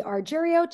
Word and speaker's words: Argyriou: For those of you that are not able to pronounce difficult Greek names with a lot of vowels, all you have to think about is Cheerio. Kathy Argyriou, Cheerio, and that's a great way to remember me.